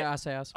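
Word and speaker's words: Assayas: Asayas. How did I pronounce his name? – Yes. Olivier Asayas.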